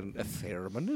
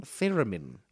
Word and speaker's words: theremin: Theremin. – Theremin. – Yes, but don't accent the I too much. Theremin.